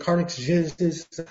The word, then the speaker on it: carcinogenesis: Reproductive toxicity and carcinogenesis, I can't pronounce it, are possible. So it's a help me with that word. – Carcinogenesis. It means it can cause cancer.